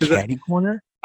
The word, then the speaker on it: caddy corner: I think it's actually caddy, C-A-D-D-Y. – Oh, is it caddy corner? – I've heard it pronounced that way, and I've seen it spelled that way.